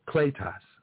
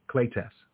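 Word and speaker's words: kletos (κλητός): Okay, and so when you understand, look at the original Greek word for call is kletos, okay, and that means to be invited, kletos. That's how you say it in Greek, and it could mean to be invited.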